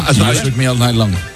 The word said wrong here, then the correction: Shook Me All Night Long: Me cuesta el Shook Me All Night Long.